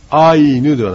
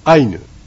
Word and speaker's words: アイヌ: はい、この単語の中でちょっと気をつけていただきたいところいくつかあるんですけども、アイヌのイですね、アイヌ、それとカムイのイですね、それと来家のイ、これは軽く発音いたします。ですからアイヌではなくてアイヌというふうに発音いたします。